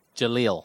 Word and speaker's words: Jahlil: Is it Jahlil or Jahlil? – Jahlil. – But it's spelled... – J-A-L-I-L, Jahlil. – I thought it was J-A-H-I-L. – Well, still Jahlil.